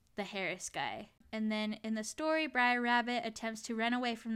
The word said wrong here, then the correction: Brer Rabbit: These are spelled like B-R apostrophe E-R. – Yeah, so I think it's short for brother. So I think it's supposed to be Brer Rabbit.